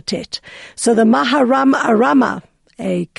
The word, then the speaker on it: Tet: We are going to be looking into the letter Tet, or Ashkenazi pronunciation, the letter Tes.